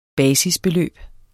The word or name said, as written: basisbeløb